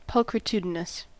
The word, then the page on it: pulchritudinous: American English